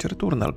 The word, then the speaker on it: Returnal: Przynajmniej niektórych, mm, jedną z nagród na tegorocznym The Game Awards wygrało Returnal, Returnal nie wiem jak to się po polsku powinno wymawiać, returnal po prostu.